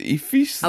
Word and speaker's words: Ephesus: That's how you pronounce it. Ephesus.